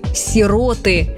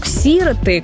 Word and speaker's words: сироты: Словари разрешают ударение только на второй слог – «сироты». Во всех падежах – «сирот», «сиротам», «о сиротах». Многие словари специально уточняют – «не сироты».